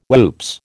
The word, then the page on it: whoops: American English